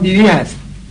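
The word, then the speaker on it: Dewey: پیش فرض چهارم این نظر جان دیوی هست. – جان دیوی، جان دیوی بله آمریکاییه. جان دیوی در البته تلفظ آمریکاییش دیویه، جان دیویه، منتها در فارسی می‌نویسن دیویی.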